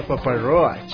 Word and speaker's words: Papa Roach: ¿Papa Roach o Papa Roach? – Papa Roach.